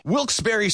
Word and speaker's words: Wilkes-Barre: And you're listening to Wilkes-Barre Scranton's 97.9X. Now, I had heard that it was supposed to be pronounced Wilkes-Barre. Wilkes-Barre Scranton's 97.9X. The only way to pronounce Wilkes-Barre.